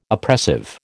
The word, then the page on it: oppressive: American English